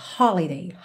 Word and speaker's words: holiday: Lembrando que holiday tem a sílaba tônica na primeira sílaba, holiday, holiday.